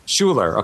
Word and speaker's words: Schuyler: His middle name, is it Schuyler? – Schuyler.